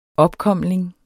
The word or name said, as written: OPKOMLINGES